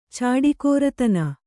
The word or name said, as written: ಚಾಡಿಕೋರತನ